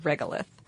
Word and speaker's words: regolith: So the dust that covers Mars is called regolith. – Mm-hmm. – If I am pronouncing that correctly. – You are.